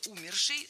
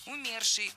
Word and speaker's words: умерший: Много раз прозвучало причастие умершей с ударением на первом слоге, но это неверно. В этом слове ударение должно падать на второй слог – умерший, умершие, умерших.